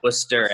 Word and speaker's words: Worcester: Yeah, you get to Worcester, and you get to, but when you're in Worcester, that's the only R you're gonna have pronounced when you're there. Yeah. Is the R Worcester.